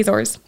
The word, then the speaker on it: Azores: So I was on the Azores, or the Azores, that's how you say it in English.